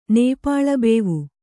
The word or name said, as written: ನೇಪಾಳಬೇವು